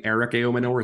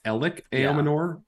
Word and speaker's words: Aominor: It's not apparently Eric Aominor, it's Elik Aominor, which I think I heard wrong like 7 times in the broadcast.